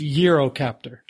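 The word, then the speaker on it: Eurocopter: We don't know how to pronounce it. It's Eurocaptor.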